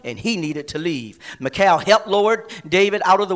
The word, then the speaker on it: Michal: David fled from Saul and went home to his wife, Michal. Some may pronounce it Michelle. David and Michelle.